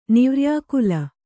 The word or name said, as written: ನಿವ್ರ್ಯಾಕುಲ